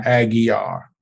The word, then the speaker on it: Aguiar: Today, we just left a press conference where we were joined by our brother, Benjamin Crump, attorney Sam Aguilar. – Aguiar. – The L is silent, right? Aguiar. Our other co-founders of Until Freedom, Angelo Pinto, Linda Sarsour.